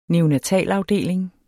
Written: neonatalafdeling

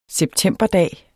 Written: septemberdag

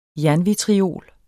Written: jernvitriol

